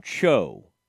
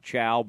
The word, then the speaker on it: Cho: I think you pronounce her name Cho. It looks like Chou, so I thought Chow, but I think you say Cho.